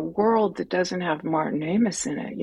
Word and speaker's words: Amis: or Ames, mm-hmm. if I'm mispronouncing it American-wise, right? Yeah. How do you pronounce it? Ames? – I say it Amis. I think it's Amis. – Okay.